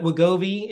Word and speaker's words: Wegovy: But Wegovy, if I'm pronouncing that correctly, is... – Yeah, you got it.